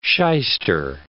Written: shyster

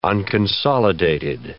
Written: unconsolidated

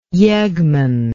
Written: yeggman

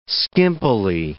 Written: skimpily

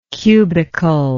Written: cubicalness

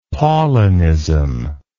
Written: Paulinism

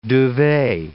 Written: duvet

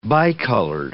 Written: bicolored

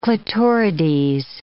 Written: clitorides

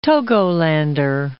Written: Togolander

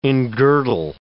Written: engirdle

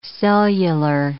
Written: cellular

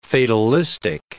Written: fatalistic

